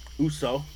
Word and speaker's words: Uso: Uso.